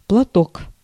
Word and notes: платок: /plɐˈtok/

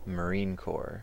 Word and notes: marine corps: American English; /məˈrinˌkɔr/; /məˈrinˌkɔrz/

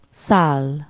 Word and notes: settle: /ˈsɛtəl/

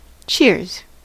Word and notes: kippis: [ˈkipːis]

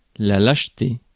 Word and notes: lâcheté: France; [laʃ.te]; French

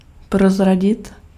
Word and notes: dénoncer: [de.nɔ̃.se]

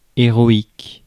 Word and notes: héroïque: France; [e.ʁɔ.ik]

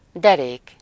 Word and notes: derék: /ˈdɛreːk/

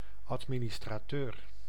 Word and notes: administrateur: /ɑt.mi.nis.tɾa.ˈtøɾ/

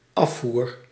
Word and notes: écoulement: France; [e.kul.mɑ̃]

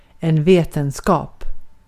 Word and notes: vetenskap: [ˈveː.tɛnˌskɑːp]

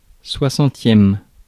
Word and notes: soixantième: France; /swa.sɑ̃.tjɛm/

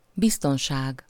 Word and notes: security: [sə.ˈkjuɹ.ɪˌti]; American English

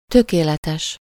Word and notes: ideal: [aɪ.ˈdi.əl]; /aɪ.ˈdɪəl/; /aɪˈdiː.əl/; /aɪ.ˈdil/

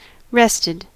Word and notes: rested: American English; /ˈɹɛstɪd/; /ˈɹɛstɛd/; /ˈres.tɪd/

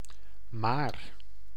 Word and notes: quoique: /kwak(ə)/

